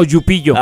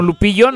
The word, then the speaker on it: Lupillo: Con doble L. Ajá. – Lupillo. – No con Y. Sí, porque luego hay quienes lo quieren pronunciar como Yupillo.